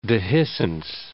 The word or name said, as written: dehiscence